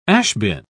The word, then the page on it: ash-bin: British English